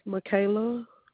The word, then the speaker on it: Mikaila: My first name is Michaela. – Spell it for me. – M-I-K-A-I-L-A.